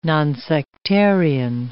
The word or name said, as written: nonsectarian